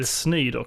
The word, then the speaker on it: Snyder: The Schneider Cut. The Snyder Cut. Sny Snyder Cut.